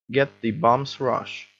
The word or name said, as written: get the bum's rush